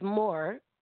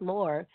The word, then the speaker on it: Moore: H-A-N. – It's like more, but it's just, it's more. Chantel Moore. It's pronounced more. It's just my dad actually found my name because it sounded like to him, like more. You would probably, as Americans, like English people, they would say Maurer or something like that.